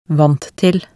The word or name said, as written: vant til